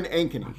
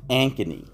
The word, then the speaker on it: Ankeny: We're not actually in Des Moines, but how do you say the name of this town? – We're in Ankeny. – Ankeny.